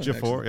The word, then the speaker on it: Ejiofor: Ejiofor, yeah.